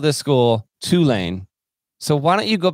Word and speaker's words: Tulane: Tulane.